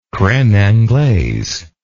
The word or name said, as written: creme anglaise